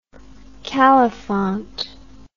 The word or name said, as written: califont